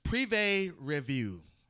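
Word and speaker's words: Privé Revaux: It's Privé Revue. So spell that. – P-R-I-V-E, Privé. And then R-E-V-A-U-X.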